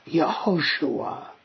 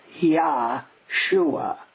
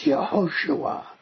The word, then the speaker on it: Yehoshua: The name Joshua in Hebrew is Yehoshua. It's not Yahshua. It's Yehoshua. That's the way it's pronounced in Hebrew, right?